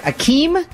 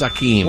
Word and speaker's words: Akeem: I hope I'm saying it right. Akeem? – Wow, that's right. Prince Akeem.